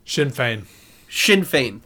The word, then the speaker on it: Sinn Féin: And they have some interesting things to talk about a man, man from, and I apologize if I pronounce it incorrectly, I think it's Sinn Féin. Sinn Féin, thank you.